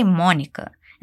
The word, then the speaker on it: Monica: Notice the pronunciation of Monica. We don't say Monica. Instead, we say Monica.